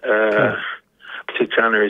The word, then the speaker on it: Atutana: Is that that's exactly how it's that's pronounced. Atutana is a man.